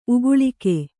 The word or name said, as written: ಉಕ್ಕಲಿಕೆ